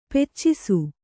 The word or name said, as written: ಪಾಚಿಸು